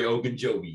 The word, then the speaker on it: Ogunjobi: Ogunjobi. Did I miss it? Butcher that? – Larry Ogunjobi, yeah.